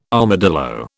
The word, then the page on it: armadillo: British English